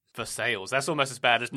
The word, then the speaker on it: Versailles: Kentucky is the weirdest state for that I know of, because there is Baghdad, Kentucky, there is Lebanon, Kentucky, there is, this is their pronunciation, Versailles, Kentucky.